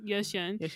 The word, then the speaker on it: Ye Xian: So the Chinese one, I'm sorry for my pronunciation already, but Yixian. Yeah. – I think it's Yeah. It's Ye, because that's what they said on YouTube. Yeah, Ye Xian.